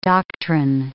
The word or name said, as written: doctrine